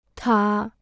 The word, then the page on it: 塔: tǎ